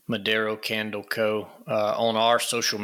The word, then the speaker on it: Modero: Modero. – So if you're listening to this, you want to support a small family-owned Christian business and get amazing fragrances out of candles, Modero.